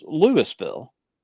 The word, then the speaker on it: Louisville: Ohio has a Louisville, but it's pronounced Louisville.